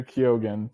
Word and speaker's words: Keoghan: Kyogen. – I've heard Kyon. I've heard Kyogen. And yeah. I at this point, Allison Williams on live television said Barry Keoghan. So that's how I'm pronouncing it at this time.